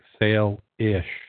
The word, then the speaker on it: Salish: This pronounced with a long A, like sail-ish.